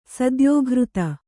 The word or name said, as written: ಸದ್ಯೋಘೃತ